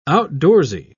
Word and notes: outdoorsy: British English

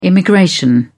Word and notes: immigration: British English

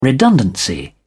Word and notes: redundancy: British English

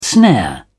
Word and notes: snare: British English

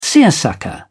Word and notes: seersucker: British English

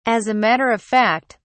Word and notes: as a matter of fact: British English

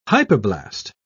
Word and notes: hypoblast: British English

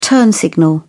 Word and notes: turn signal: British English

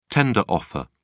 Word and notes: tender offer: British English

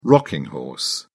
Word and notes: rocking horse: British English

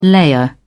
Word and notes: layer: British English

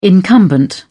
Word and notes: incumbent: British English

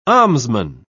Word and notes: almsman: British English

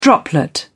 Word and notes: droplet: British English

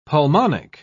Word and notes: pulmonic: British English

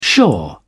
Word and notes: shore: British English

